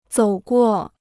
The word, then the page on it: 走过: zǒu guò